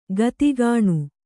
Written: ಗಿದ್ಗಾಣಿ

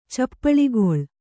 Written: ಚಪ್ಪಳಿಗೊಳ್

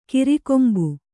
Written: ಕೊರೆಕಂಬಿ

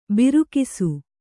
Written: ಬೀರಗಾಸೆ

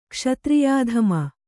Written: ಕ್ಷತ್ರಿಯಾಧಮ

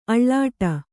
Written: ಆಲೀಢ

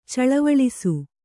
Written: ಚಳವಳಿಸು

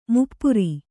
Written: ಮುಪ್ಪುರ

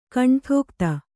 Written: ಕಂಠೋಕ್ತ

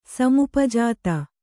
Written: ಸಮುಪಜಾತ

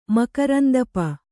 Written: ಮಕರಂದಪ

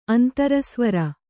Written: ಅಂತರಸ್ವರ

